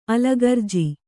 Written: ಅಲಗರ್ಜಿ

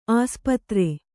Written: ಆಸ್ಪತ್ರೆ

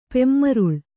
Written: ಪೆಮ್ಮರುಳ್

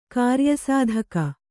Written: ಕಾರ್ಯಸಾಧಕ

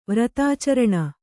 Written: ವ್ರತಾಚರಣ

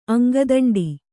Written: ಅಂಗದಂಡಿ

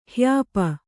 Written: ಹ್ಯಾಪು

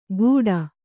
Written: ಗೂಡ

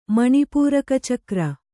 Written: ಮಣಿಪೂರಕಚಕ್ರ

